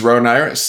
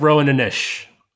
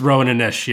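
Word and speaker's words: Roan Inish: Is this Rowan Irish? The Secrets of Rowan Irish? – The Secret of Rowan Anish. – Oh, is that how that's pronounced? – It's Rowan Anish, yeah.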